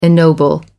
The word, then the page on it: ennoble: American English